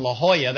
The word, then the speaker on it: La Jolla: It's in La Jolla. That's spelled J-O-L-L-A. Some of you know that. It looks like La Jolla, but they don't say the J. It's an H.